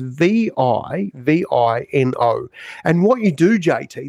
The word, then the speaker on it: Vivino: The answer is this app, and it's called Vivino. I think that's how you say it. It's Vivino.